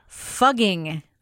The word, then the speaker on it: Fugging: Starting January 1st, the village of fucking will now be called Fugging. the F word has no meaning in German, and fugging or fugging is pretty much the way the townsfolk have pronounced it for the past 1,000 years.